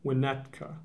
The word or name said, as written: Winnetka